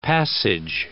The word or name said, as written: passage